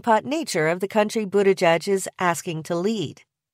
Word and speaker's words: Buttigieg: Buttigieg is probably the best way for English speakers to say Buttigieg's name.